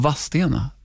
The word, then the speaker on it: Vadstena: Eh, jag läser upp här innan jag läser så ställer jag frågan, varför uttalar man det inte Vadstena och Vastena som det ska vara? Vadstena som... heter det ju. Ja, men varför inte Vadstena? – Mm. Det där är ju en dialektal grej.